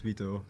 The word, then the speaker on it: Wito: So, all right, my name is Wito.